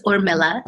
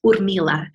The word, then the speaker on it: Urmila: And if you pronounce it with a Russian accent, it's Urmila, which a convenient diminutive would be Mila.